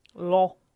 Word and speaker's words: Loire: How would you say that? – Loire.